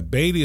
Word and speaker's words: Beatty: Can you spell your first name is H-U-G-H. Can you spell Beatty? – Yeah, Beatty is spelled B-E-A-T-T-Y. – B-E-A-T-T-Y, for those of you guys that are writing down his website.